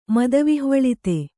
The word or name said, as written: ಮದವಿಹ್ವಳಿತ